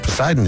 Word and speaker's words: Poseidon: A not silent P that I feel like should be silent. You don't want to say it would just be Poseidon? – Yeah. Instead of Poseidon or Sidon.